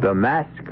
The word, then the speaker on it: masque: Mask of the Red Death. The word mask, you should know, is spelled M-A-S-Q-U-E and means masquerade.